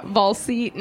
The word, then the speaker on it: Wallsé: About a robot is spelled W-A-L-L-E. So it's just like missing the S, but that's where like the dash is. It's a silent S, I think. Yeah, Valse.